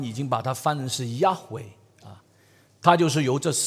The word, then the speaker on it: Yahweh: 到了后期，已经没有什么人知道怎么正确来去念这个 Y H W H，但是近代的学者他们认为呢，这个 Y H W H 不应该把它把它发音成 Jehovah，应该把它比较发音成 Yahweh。